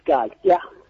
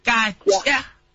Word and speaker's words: Gatya: Mthandazo, I hope I pronounced your surname correctly. Welcome to the Rise FM Drive. – Hi, hi, thank you so much for having me. Uh, it's Gatya. Gatya.